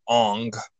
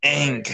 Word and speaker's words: Aang: Instead of calling him Aang, they call him Ong. Like, no, dude, it's pronounced Aang.